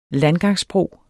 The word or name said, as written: landgangsbro